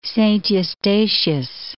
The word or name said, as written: Saint Eustatius